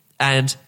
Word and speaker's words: v: Second thing I learned is when there's like the V, you know, this V this, it's pronounced and, which is why it's Stambovsky V Ackley.